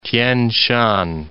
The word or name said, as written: Tien Shan